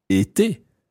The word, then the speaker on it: été: So the past participle of être is été.